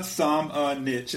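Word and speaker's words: Šamanić: Is that how we're saying it? – Yeah, I just looked at the pronunciation guide. So let me read this real quick. So yeah, it is Luka Samanich is what they put in there. – So. Okay. Yeah. That's what we're going with.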